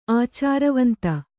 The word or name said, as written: ಆಚಾರವಂತೆ